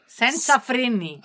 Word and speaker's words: Senza freni: 첫 번째 곡이 제가 발음이 맞나요? 모르겠네요. 센제나 프레니. – Senza freni. – Senza freni. – Correct. Senza freni.